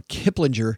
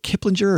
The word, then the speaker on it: Kiplinger: First headline comes to us from Kiplinger. I, I never know if it, is it Kiplinger or Kiplinger. Um, I prefer the soft G.